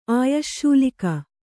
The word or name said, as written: ಆಯಶ್ಶೂಲಿಕ